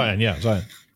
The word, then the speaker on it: Zeihan: You just did an interview with Peter Zeihan, I think, talking about, am I pronouncing his name correctly? – Zeihan.